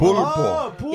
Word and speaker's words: Pulpo: No, it's like so he uses Pupil beard oil. – He uses, excuse me, what? – Pupil's beard oil? – What? – Pulpo beard oil. – Pulpo. – Yeah, sorry, I probably pronounced it wrong.